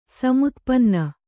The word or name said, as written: ಸಮುತ್ಪನ್ನ